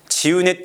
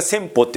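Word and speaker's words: 千畝: チウネって読めないんだよね。千に本って読んじゃってね。で、それが千歩って呼ばれて、でそんなのいねえとかって言って、外務省に、そんな人いねえとかって外務省にユダヤ人が問い合わせてもですね、相手にしてくれなかった。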